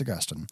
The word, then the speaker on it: Augustine: Because, you know, you've got St. Augustine Grass, St. Augustine Florida, but no, it's Augustine.